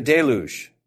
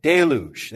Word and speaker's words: deluge: Letter A there says, after the deluge. See, if you write a Greek lexicon, you can't say flood, because that's just a simple word. Deluge. Yeah, there you go. Deluge. Yeah, because the French pronunciation there.